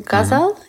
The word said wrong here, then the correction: Ghazni: Is that how I pronounce it? – Ghazni. – Sorry, Ghazni. – That's right, yeah.